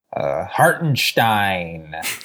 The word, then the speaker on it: Hartenstein: No, but in the words of Kevin Harlan, Hartenstein.